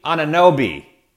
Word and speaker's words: Anunoby: Then they have those guys who come off the bench like OG Unu. Oh, I butchered that name. OG Anunoby.